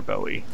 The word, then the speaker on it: Bowie: Yeah, you're aware of this David Bowie character? Bowie, I believe it's pronounced.